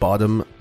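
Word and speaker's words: Bodom: And welcome to this true crime episode all about the Lake Bottom murders. That's B-O-D-O-M, not B-O-T-T-O-M.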